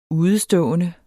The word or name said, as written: udestående